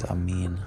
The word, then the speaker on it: Amin: Dude's name is Amin. Rhymes with King.